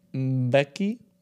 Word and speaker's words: Mbeki: So this theory has a number of high-profile believers, including South African President Thabo Mbeki. I don't know how that's pronounced, but that sounds right. Mbeki.